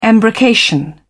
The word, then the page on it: embrocation: British English